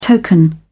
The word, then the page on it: token: British English